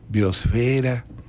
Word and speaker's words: biosfera: La única que tiene la pronunciación que nosotros usamos como mexicanos es atmósfera. Lo demás debe ser hidrosfera, litosfera, biosfera.